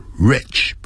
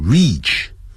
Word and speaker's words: rich: 好，我们也可以讲 however rich you are， rich 不要念成 reach，不管你多么有钱， You can't buy good health，你就买不到健康啊，这实在话。